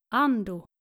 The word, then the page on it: Ando: nordische